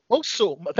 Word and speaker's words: Mosul: The Russo brothers from the Avengers Endgame have a film on Netflix called Mosul. It's an action film that plunges viewers into the fight between the Renegade Police Unit and the ISIS fighters who have destroyed their homes. Okay, wow. – Mosul.